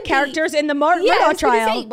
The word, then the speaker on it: Murdaugh: Okay, so it's spelled M-U-R-D-O-U-G-H. – Oh, so it's technically like, Murdaugh. – I get it. – It's technically Murdaugh. Everyone in the documentary said Murdaugh, like literally Richard, Rupert, whatever. – I just did the same thing. – Rupert's family, but it's spelled differently and it's Murdaugh.